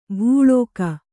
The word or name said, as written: ಭೂಳೋಕ